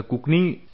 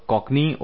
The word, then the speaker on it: Cockney: In this audio lesson, I'll talk about the Kokni school of poetry. Though you can pronounce this either way, either Kokni or Kokni, you know, it doesn't matter. You should just know the spelling, which is C-O-C-K-N-E-Y.